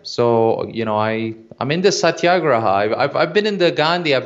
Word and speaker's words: satyagraha: Well, I'm not certain that this is how it's pronounced, but I'm pretty sure it's satyagraha. Because I think that's how Michael Nagler pronounces it.